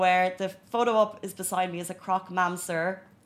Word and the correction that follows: croque monsieur: A croque monsieur, that is my terrible French pronunciation of it. Let me try that again. Croque monsieur is a, a uh, traditional French dessert.